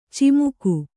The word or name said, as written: ಚಮಕ